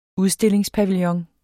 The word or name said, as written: udstillingspavillon